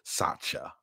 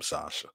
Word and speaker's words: Satya: Satya, that's how they pronounce it. Satya. Yeah, I mean, I call him Sasha.